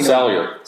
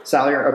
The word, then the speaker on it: Salyer: But, you know, Jemery Sailor, Slayer, Sawyer? I don't know how to say his name. S-A-L-Y-E-R. See, I pay Sawyer. – Sawyer.